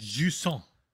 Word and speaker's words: Jusant: Jusant. In their review guide, they literally say, this is how you pronounce it, Jusant.